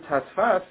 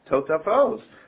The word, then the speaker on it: Totafos: He will read the word Tasfas, even though it's pronounced Totafos.